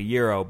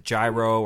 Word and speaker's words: gyro: To clarify to the folks at home, that he's pronouncing it properly, euro, but you might pronounce it gyro or gyro.